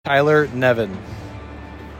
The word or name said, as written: Tyler Nevin